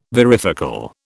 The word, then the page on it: varifocal: British English